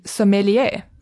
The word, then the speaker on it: sommelier: And there's something else that we see on the menu, and that is that this is being recommended to us by the sommelier.